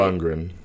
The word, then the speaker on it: Lundgren: Did I say that right? – Lundgren. – Lundgren? – Yeah.